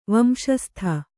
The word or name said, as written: ವಂಶಸ್ಥ